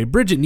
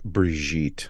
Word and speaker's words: Brigitte: Either way, Bridget Nielsen is there playing Drago's wife again, except she's not his wife now cuz fuck her. I believe it's pronounced Brigitte.